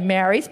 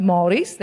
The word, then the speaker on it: Māori: Now, the thing about that school of 1,000 is there are only two Māoris in it, or you might say Māoris, but it's actually pronounced Māoris.